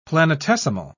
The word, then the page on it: planetesimal: British English